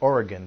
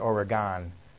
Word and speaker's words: Oregon: By the way, they pronounce it Oregon there, not Oregon, the way some people do who are uninitiated. Up there they say Oregon, as if it was spelled with G-U-N.